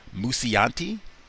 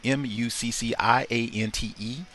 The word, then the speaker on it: Mucciante: Now, I believe you pronounce his name Timothy Mucciante. M U C C E I, excuse me, M U C C I A N T E, Mucciante. I think that's how you say it, but he was the one.